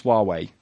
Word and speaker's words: Huawei: How do you pronounce Huawei? – You got it right, Huawei.